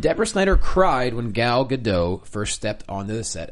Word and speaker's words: Gadot: I'm pretty sure it's Gadot now. – Keep going back. I was saying Gadot for a while. – Yeah, it's Gadot. Look, it's Gadot. – You know, when I see escargot, I pronounce it escargot usually. – That's French, and she's from Israel. – Good. So if you pronounce it escargot, then you can say Gadot. – Then I'll say Gal Gadot.